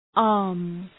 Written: arms

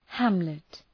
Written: hamlet

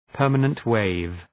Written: permanent wave

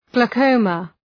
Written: glaucoma

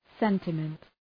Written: sentiment

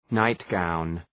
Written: nightgown

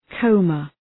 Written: coma

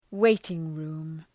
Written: waiting-room

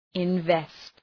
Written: invest

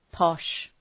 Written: posh

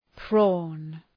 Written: prawn

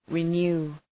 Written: renew

